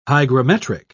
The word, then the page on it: hygrometric: British English